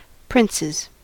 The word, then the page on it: princes: American English